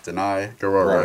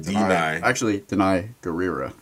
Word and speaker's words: Danai Gurira: I've been telling you how to say it for weeks. – Danai Gurira. Right. And look, I put it in parentheses how to pronounce it. Actually, Danai Gurira.